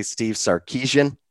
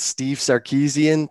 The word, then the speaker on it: Sarkisian: But so many people say Steve Sarkisian. – Yeah. – But I think it's Steve Sarkisian.